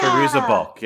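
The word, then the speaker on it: Fairuza Balk: So, Feruza, I don't know how to say it. – Yeah. Feruza Balk, yes.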